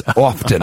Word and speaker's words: often: Which happens often. – I've seen that. – It happens so often because I don't pronounce the T in that word that whenever I say often.